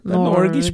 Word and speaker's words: Norge: Norge. Norge. Norge. Norge. Yeah, like Norge. That. Like the, you have to say it like the Swedish Norge.